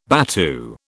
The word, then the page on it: battue: British English